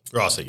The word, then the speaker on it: Rossi: Is it Rossi? – Rossi, yep. – Okay, like the firework people. – That's Rossi. It's R-O-S-S-I.